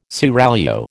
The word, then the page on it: seraglio: American English